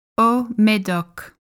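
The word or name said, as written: Haut-Médoc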